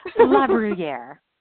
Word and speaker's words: Labruyere: It's Labruyere.